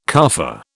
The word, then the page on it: carfare: British English